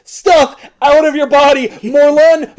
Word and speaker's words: Morlun: And he said, it's pronounced Morlun, like in Gun.